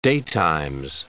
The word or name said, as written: daytimes